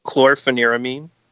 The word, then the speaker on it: Chlorpheniramine: Chlorpheniramine. – Whoa, what did you have to say it so fast? – Chlor. – Hold on. Chlor. – Phenaramine. Phenaramine is pH. Phenaramine. – Chlorpheniramine. Phen. P-H-E-N like fin.